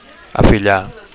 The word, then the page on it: afillar: Catalan